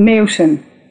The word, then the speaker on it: Meeuwsen: Hi, I'm Ivonne Meeuwsen.